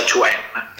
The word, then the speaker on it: quan: Tai Chi è scritto, diciamo, Quan, che si pronuncia Chuan.